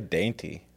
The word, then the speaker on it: Dante: It's spelled D-A-N-T-E. How do you think it's said? – I would say dainty. – It is Dainty. – Okay. – Dainty. – Everybody says Dante.